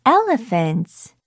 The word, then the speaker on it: elephant: 우리 요거 스펠링 보면요, E-L-E-P-H-A-N-T 이렇게 되는데 PH가 F 이렇게 F 사운드로 발음된다는 것도 같이 알아두면 좋을 것 같아요.